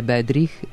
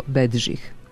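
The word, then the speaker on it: Bedřich: Ali čini mi se da se na srpskom Smetana zove Bedžih, a ne Bedrih. – Kao što se češki kompozitor Antonin ne preziva Dvorak, nego Dvoržak, reditelj Menzel nije Jiri, nego Jirži, tako ni Smetana nije Bedrih, nego Bedžih.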